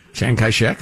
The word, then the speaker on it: Chiang Kai-shek: when Chiang Kai-shek, or however you say his name. – Chiang Kai-shek, there you go. I believe, yeah. – FTA, F-A-T, F-T-C, whatever.